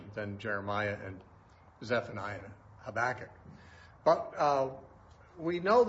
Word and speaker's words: Habakkuk: Now Habakkuk, or Habakkuk probably is a better pronunciation, was prophesying alongside Jeremiah also.